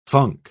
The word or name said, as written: funk